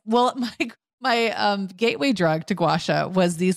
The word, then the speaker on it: gua sha: So it's two words, gua, G-U-A, sha, S-H-A. But when I hear people say it, it kind of comes out almost like one word, like gua sha.